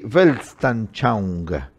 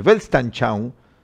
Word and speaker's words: Weltanschauung: La idea alemana, en alemán, creo que se pronuncia así, Weltanschauung.